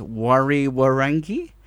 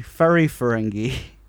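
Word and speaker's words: Whariwharangi: When you see the words, you think it's Wari Warangi, but it's actually Furry Ferengi. – So WH equals F.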